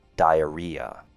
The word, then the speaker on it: diarrhea: Di has the same sound as die, like when someone passes away. A has a short A sound, and rhea. If I were to spell it, it would look like R-E-E-A, rhea, diarrhea. Moving on to vocabulary.